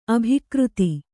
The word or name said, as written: ಅಭಿಕರ್ತೆ